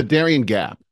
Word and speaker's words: Darien Gap: The Darien Gap.